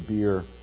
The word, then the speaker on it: Be'er: This is really, when you pronounce it in the Hebrew, it's Be'er, which means a well.